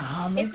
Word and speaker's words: homage: Now, homage? – It's a French word. Right. Homage is the English pronunciation, but it's a French word. – Yeah, it's homage. I think that we're just in a world that's so sensitive.